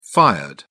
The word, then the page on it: fired: British English